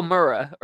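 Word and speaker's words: Uemura: He wrestled Yuya Umanero. Is that how you say it? – No, Yuya no? Uamura or Yuyamura. – Oh, I've been saying it so wrong.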